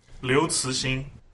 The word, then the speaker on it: Liu Cixin: Liu Cixin.